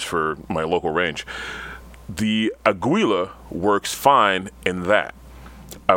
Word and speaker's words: Aguila: Aguila? Is that how you say it? – Aguila.